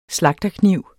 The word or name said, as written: slagterkniv